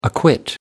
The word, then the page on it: acquit: American English